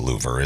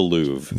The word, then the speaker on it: Louvre: It's not pronounced Louvre, is it? It's the Louvre.